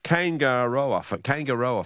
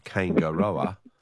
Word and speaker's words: Kaingaroa: Yes, that's what I was hoping people would get through my croaky pronunciation of Kaingaroa. Which does sound a bit like Aotearoa, doesn't it? – Well, something like that, Something New Zealandish.